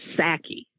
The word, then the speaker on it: Psaki: And Biden's press secretary, Jean, I think it's pronounced Psaki. It's P-S-A-K-I, Psaki.